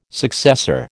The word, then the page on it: successor: American English